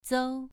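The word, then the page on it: zou: tone 2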